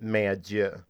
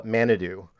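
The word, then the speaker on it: Main-à-Dieu: So in Canada, on the East Coast, in Cape Breton, there is a place called, in French, you pronounce it Mayadieu. But the locals, they're English, call it Manadou.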